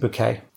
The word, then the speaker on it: Bucket: who's married a bloke called Mr. Bucket, but she insists on pronouncing it Bouquet.